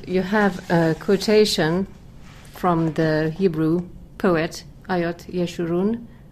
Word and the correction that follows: Avot Yeshurun: Did I pronounce it somehow right? – It's a pseudonym. His name is Avot Yeshurun.